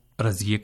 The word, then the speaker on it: رضیہ: سامعین، آپ نے یہاں جو جملے سنے ان میں خواتین کے جو نام ہیں رضیہ، ناصرہ اور نسیمہ، ان کا فارسی تلفظ آپ نے ملاحظہ کیا کہ رضیہ کو رضیے کہا گیا، ناصرہ کو ناصرے کہا گیا اور نسیمہ کو نسیمے.